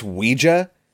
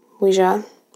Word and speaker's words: Ouija: Wendy corrects the pronunciation of Ouija, and she's like, it's Ouija for the French word and the German word for yes. – It's a Ouija board. – Ouija. It originates from the French and German language, meaning yes.